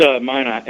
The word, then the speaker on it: Minot: Now, you said, I think, is that Minot? Is that how you say it? – It's Minot, M-I-N-O-T.